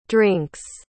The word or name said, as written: drinks